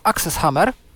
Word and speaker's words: AXS Hammer: Istnieje wtyczka do Firefoxa i do Chroma, nazywa się Access Hammer, pisze się AXS Hammer.